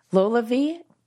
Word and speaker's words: LolaVie: I just started using LolaVie.